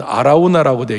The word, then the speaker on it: 오르난: 오르난의 타작마당에서 다윗이 번제와 제사를 드린 것입니다. 그 타작마당을 샀어요. 그 타작마당을 그냥 그 오르난에게 우리 다른 식의 발음은 아라우나라고 되기도 하지만 이